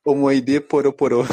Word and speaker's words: Omoide Poroporo: Omoide Poroporo.